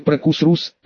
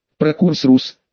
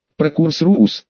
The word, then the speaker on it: Прокурсрус: Прокурсрус, ударение на первую у, не на вторую, на первую.